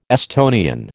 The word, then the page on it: Estonian: American English